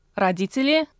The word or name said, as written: parents